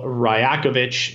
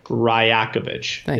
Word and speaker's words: Rajakovic: We got, obviously, the Raptors brought in Darko Rajakovic, is how you pronounce his name, for those that are like, how the fuck do you say that? Rajakovic is how you pronounce his name.